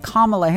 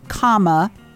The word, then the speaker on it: Kamala: And by the way, for those of you who don't know how to pronounce her name, you know how to pronounce the word comma, like what you put in a sentence to break up phrases. C-O-M-M-A. Well, that's the pronunciation of her name. Kamala.